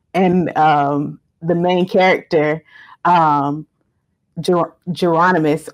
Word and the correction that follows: Jeronicus: I don't want to pronounce mispronounce his name, Jeronicus.